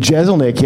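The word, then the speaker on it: Jeselnik: And I'm going to pick good name tags, not like those jerks, not like fucking Kyle Kinane and Jezelnik yesterday.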